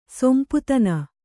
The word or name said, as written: ಸೊಂಪುತನ